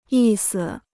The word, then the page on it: 意思: yì si